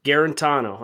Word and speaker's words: Guarantano: Guarantano.